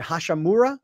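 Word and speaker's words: Hachimura: And the kid they got from Washington, Rui, is it Hashimura? Am I saying his name right? – Yes.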